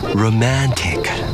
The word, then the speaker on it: romantic: 注意我的嘴发音，舌头不能太硬，要软一点。 romantic romantic romantic。